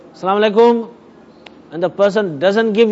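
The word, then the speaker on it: Assalamu alaikum (السلام عليكم): So it is properly pronounced, Assalamu alaikum.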